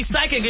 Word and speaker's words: psychic: So you're right, because when we see psychic, it's spelled P-S-Y, right? – I couldn't tell you. – Yes, yeah. This, yep. – So the P is silent.